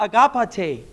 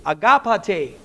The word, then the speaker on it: agapate: It's pronounced agapate, agapate, which means a love like feelings.